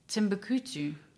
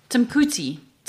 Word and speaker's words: Timbuktu: Timbukutu. – I just want you to say that again to hear if you're going to bastardize it. – Wow. Timbukutu. How do you say it? Timbuktu.